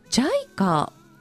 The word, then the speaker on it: JICA: JICA、 JICA と書いて JICA と読みます。 JICA 日系社会青年ボランティア OG でいらっしゃいます木下直子さん、お越しいただきました。木下さん、よろしくお願いいたします。 – よろしくお願いいたします。 – さて、まず JICA ということなんですけれども、皆さん聞かれたことありますかね。